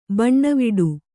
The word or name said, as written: ಬನಾವಟಿ